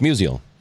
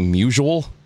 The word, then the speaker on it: Musial: Yeah, it's Musial. – Yes. – Because people say Musial. – Yeah. – Which like, is not right. – It's the Stan Musial version of nuclear.